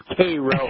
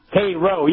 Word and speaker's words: Cairo: People think it's Cairo, but it's Cairo. – Is that how they pronounce it? – Cairo, yeah.